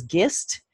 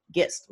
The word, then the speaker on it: Gist: it was gist and i would have to spell it g-i-s like in sam t gist like the gist of the story i had a brownie troop leader who said i'm calling you gish i said wait wait